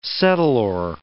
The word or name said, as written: settlor